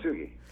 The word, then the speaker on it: Kintsugi: Kintsugi.